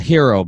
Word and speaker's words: Herro: A hero, by the way. He told me that's how he wants to pronounce.